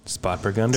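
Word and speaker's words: Spätburgunder: Spat Burgunder? – Spat Burgunder. Just got to say it like that too.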